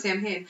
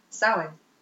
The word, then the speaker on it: Samhain: I always thought it was Samhain. Because that's kind of, I feel like that's how everybody pronounces it. – When you look at it, that's what it would be. But in the old language, that is Samhain.